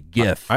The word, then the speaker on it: GIF: 68% agree that it's with the hard G. GIF.